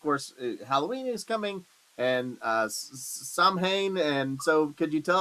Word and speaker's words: Samhain: I want to tell you first, it's pronounced Samhain. – Samhain, thank you. – Samhain, yeah. – There we go, appreciate it. – It's Irish Gaelic, which is like, it's like French. They have a whole bunch of different letters and words that they don't say. – There you go. – So, and then they have some that are pronounced differently.